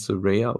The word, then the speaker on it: Saraya: So gab's gab's erstmal mal 'ne Saraya-Promo Saraya, Entschuldigung. Ne? Wird ja anscheinend Saraya ausgesprochen.